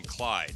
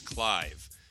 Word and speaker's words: Clive: So people would say his name wrong all the time. They call him Clyde. He's like, my name's not Clyde, it's Clive, right?